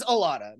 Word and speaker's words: Ilana: Her name's Ilana, not Lana. You had that wrong.